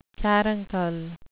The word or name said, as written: caruncle